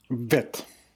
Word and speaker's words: Vit: Vitt.